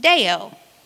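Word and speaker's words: deo (δέω): The word bind in Matthew 18:18 is the Greek 12:10, and it's pronounced deo.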